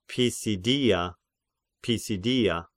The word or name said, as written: Pisidia